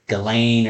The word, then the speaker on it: Ghislaine: It's spelled funny, but I believe it's pronounced Ghislaine or Ghislaine Maxwell.